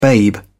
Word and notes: babe: British English

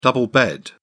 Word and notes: double bed: British English

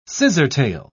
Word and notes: scissortail: British English

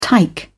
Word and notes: tike: British English

